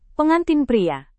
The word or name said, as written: pengantin pria